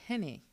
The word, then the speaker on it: hene: But the H-E-N-E, they pronounce Henne, which means king in Akan.